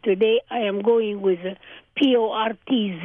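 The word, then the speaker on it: Porț: In Romanian, it's spelled P O R T with a little apostrophe under the T, which makes the pronunciation port. In Hungarian, it's spelled P O R C because the C in Hungarian is a sound of T but see,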